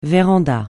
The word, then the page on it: véranda: francais